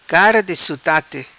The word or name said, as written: Cara Di Sutate